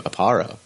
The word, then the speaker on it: Aparo: Jim Aparo. Did I say that right? – Yes.